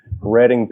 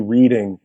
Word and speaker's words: Reading: One time I did a show in Reading, Pennsylvania, and I said, "Thank you, Reading," because it's spelled R-E-A-D. And they were like, "Reading."